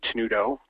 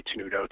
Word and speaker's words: Tenuto: It's just Jay Tenuto, just my name, Jay Tenuto, T-E-N-U-T-O,